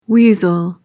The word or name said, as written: weasel